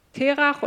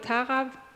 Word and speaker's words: Terach: Und zwar passiert etwas Neues mit der Linie von Noah über Sem, Terach oder Tara, wird unterschiedlich ausgesprochen.